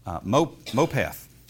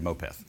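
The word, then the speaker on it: mopeth (מופת): That's mopeth. It almost is pronounced like moped, but it's actually mopeth.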